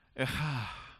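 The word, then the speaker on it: Echa (איכה): Which in Hebrew, if I can pronounce it somewhat right, is something like Echa. And you got to say it with that catch in your throat, you know? Echa.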